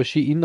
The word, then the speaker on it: Shein: Shein ist oder Schein geschrieben, aber Shein anscheinend ausgesprochen,